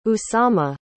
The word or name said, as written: usamah